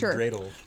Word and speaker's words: dreidel: Or like a dirtle? – A dirtle? – Okay, well, that's two different things. – It's pronounced dreidel.